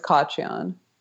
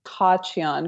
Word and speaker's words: Khachiyan: It's Khachiyan. – Is it Khachiyan? – Yeah. It's technically Khachiyan because it's an Armenian name. You don't say the K, but that sounds like you're like choking on a pubic hair, you know? – That's exactly what it sounds like.